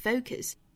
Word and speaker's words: focus: 英音的话我们读成 o focus，美音呢我们注意读成 focus。 o focus focus。